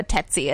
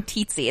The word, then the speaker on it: tsetse: Yeah, but she said something about a tetsy, and I was like, what the fuck? And I realized was saying tsetse. It's spelled T-S-E-T-S-E.